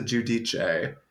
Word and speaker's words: Giudice: YouTube Teresa Giudice. That's G-I-U-D-I-C-E. – I love how you're doing the correct pronunciation. – I don't know any other way to pronounce it.